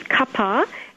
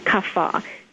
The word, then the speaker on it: kapha: And in India, there's two ways of pronouncing it. There's kapha and kapha. So it depends on if you're South Indian or North Indian, the pronunciation.